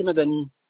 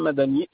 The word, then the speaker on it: Madani: Monsieur Fakri Madani. – Ouais. Comment s'écrit le nom de famille? – Fakri, F-A, oui. – K-R-I, plus loin Madani, M-A-D-A-N-I.